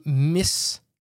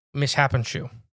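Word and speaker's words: Mishipeshu: The Mishapishu. The Mishapishu. – That seems all right. – That's how it's spelled. Like mishappens to you. – Yeah. – Mishapishu.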